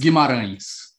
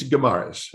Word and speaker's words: Guimarães: Um, how do you pronounce Guimarães? I think Ulysses Guimarães. yeah, that's, right. Uh, Ulysses Guimarães um, um, is um, uh, the the assembly itself is inheritance from the military rule.